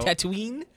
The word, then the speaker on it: Tatooine: Tatooine. – Tatooine, right? – Yeah, yes, Tatooine.